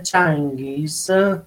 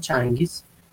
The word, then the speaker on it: Changiz: Vabbè, allora io appunto sono persiano, il mio nome Changhis, pronunciato proprio così in persiano, Changhis, è quello esattamente soprannome di Gengis Khan, quello che voi in italiano lo pronunciate Gengis Khan.